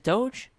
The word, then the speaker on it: Doge: Wait, is it Doge? – Doge. – Doge. Okay, Doge.